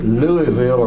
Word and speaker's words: Louisville: And she was from the city of Louisville, or Louisville, whatever you want to pronounce it.